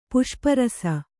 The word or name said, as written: ಪುಷ್ಪರಸ